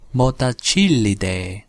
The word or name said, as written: motacillidae